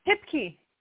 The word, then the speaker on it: Hipke: How do you say your last name? – Hipke. You got it.